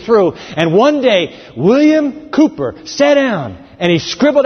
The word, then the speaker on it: Cowper: It's spelled Cowper, but it really is pronounced Cooper.